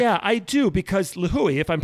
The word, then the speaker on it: Lihue: In Lihue, is that how you pronounce the name of the town? Lihue. Lihue.